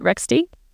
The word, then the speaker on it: Rextie: Am I pronouncing the company name right? Is it Rextie? – Perfect.